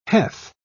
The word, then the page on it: heth: British English